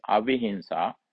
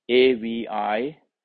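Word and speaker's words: avihiṃsā: Third part of sammasankappa is avihinsa, AVIHINSA. NSA long A. AVIHINSA long A. Avihinsa, harmlessness.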